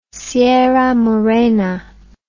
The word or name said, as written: sierra morena